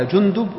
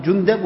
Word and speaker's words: جندب: وجندب ينطق هكذا جندب وجندب، كما قال ابن حجر أنه بضم الجيم وبضم الدال وفتحها، يعني يجوز أن نقول جندب ويجوز أن نقول جندب، كلاهما صحيح.